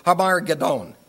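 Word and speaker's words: Har Megiddon: And they gathered them together to the place which in Hebrew is called," and this is the way you pronounce it in Hebrew, "Har Gedon," which is referred to by most English people as Armageddon.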